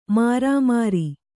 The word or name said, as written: ಮಿರಿಮಿರಿ